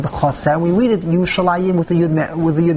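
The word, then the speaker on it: Yerushalayim (ירושלים): We pronounce it, Kriv Chesed, we pronounce it Yerushalayim. Yim, Yud Mem.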